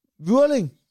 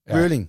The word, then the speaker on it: Vueling: Hvordan fanden udtaler man det? – Vyrling. – Okay, du har også et hjælp. Ja, det er sgu derfor, han gider at hænge ud med os. – V-U-E-L-I-N-G. Vyrling. Ja.